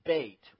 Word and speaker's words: Beit: When you see Beit or Beth, that word means house. And so Bethlehem is house of food. So anytime you see Beth, and it's pronounced Beit.